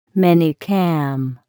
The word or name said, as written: minicam